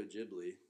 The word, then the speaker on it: Ghibli: Well, next weekend we're going to our second trip to Studio Ghibli.